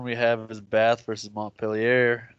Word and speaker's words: Montpellier: It's French. You don't say the R. – All right, this is in America where we pronounce the words correctly. – I don't think that's how it's pronounced, really. – It's Montpellier.